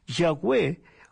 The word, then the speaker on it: Yahweh: En hebreo se describe con YHWH sin vocales. Cuando se agregan las vocales se pronuncia Yahweh, que en español sería Jehová.